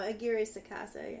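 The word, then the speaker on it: Aguirre-Sacasa: Aguirre Sacasa. Yeah. – Aguirre Sacasa. – That R has a special pronunciation in Spanish. It's like an extra trill.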